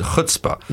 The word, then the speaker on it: chutzpah: It's spelled C-H, but you pronounce it chutzpah.